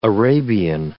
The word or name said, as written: Arabian